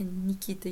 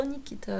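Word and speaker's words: Nikita: Люка Бессона вы можете знать по таким фильмам, как «Такси», а, «Никита», «Никита», ударение на последний слог, представляете? Никита. Я всегда читала Никита, а как казалось, нет.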